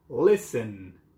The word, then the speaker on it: listen: Listen. Dinlemek. T harfini telaffuz etmiyoruz değil mi? Listen.